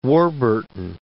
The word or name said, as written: Warburton Creek